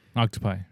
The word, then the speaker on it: octopi: I don't think he likes sushi Octopi. – Either. – Octopi, that's right. – And it rhymes with hentai.